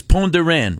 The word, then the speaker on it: Pont de Rennes: You keep saying Le Pont de Rennes. – Yeah. – Do not pronounce the S. – Yeah. – And le pont, the bridge, is like the word for an animal paw, like the leg, the foot, you know? – Yeah, yeah.